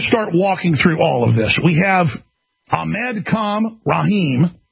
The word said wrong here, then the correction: Ahmad Khan Rahami: Did I pronounce that right? Rahami. Ahmad Khan Rahami.